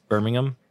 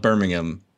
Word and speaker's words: Birmingham: Birmingham? – Yeah. You don't pronounce the H.